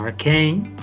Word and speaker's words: Arcane: Exacto, es que justamente en el proyecto de Arcane, o Arcane, para aquellos que pronuncian inglés y todo eso,